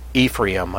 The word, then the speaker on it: Ephraim: The envy also of Ephraim, as some people pronounce it as Ephraim, I think, shall depart, and the adversaries of Judah shall be cut off.